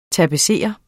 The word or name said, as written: tapetserer